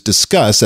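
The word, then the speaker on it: Disqus: And I use Discuss, that's spelled D-I-S, WordPress.qus.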